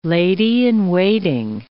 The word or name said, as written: lady-in-waiting